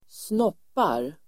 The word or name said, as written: snoppar